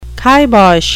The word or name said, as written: kibosh